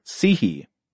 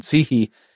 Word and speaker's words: psyche: For example, the word psihe, it's spelled psyche, you know, in the English pronunciation, psihe.